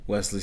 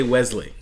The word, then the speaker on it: Wesley: Do you pronounce it Wesley or Wesley? – Wesley. – Wesley? – Wesley. – Wesley. – Wesley. – This is Wesley situation. Wesley? – There's no Z there.